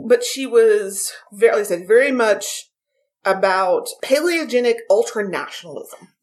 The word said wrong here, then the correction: palingenetic: Palingenetic means...